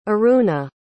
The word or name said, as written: Aruna